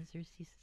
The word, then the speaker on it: Xerces: It's X E R C E S. Uh, let's see, Xerces is how it's pronounced.